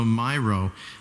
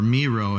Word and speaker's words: Miro: Or it has iTunes and also Miro, uh, or Miro if you pronounce it that way, M I R O.